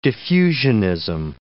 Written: diffusionism

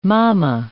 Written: mamma

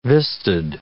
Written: vistaed

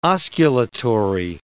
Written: osculatory

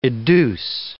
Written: educe

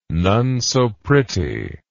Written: nonesopretty

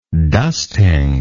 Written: dusting

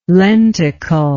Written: lenticle

